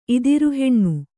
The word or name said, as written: ಇದಿರುಹೆಣ್ಣು